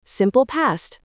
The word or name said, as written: simple past